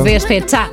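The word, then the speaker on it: Despechá: Despecha. – Despecha. Perché deve avere l'accento finale?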